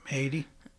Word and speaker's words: Haiti: Ramonda goes to visit Nakia in Haiti or whatever. I don't know how to say it. – Yep, Haiti.